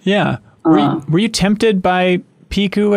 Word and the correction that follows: Pichu: at all? Pichu? How do you pronounce that stupid thing? – Pichu, Yoshi main up in here saying Piku.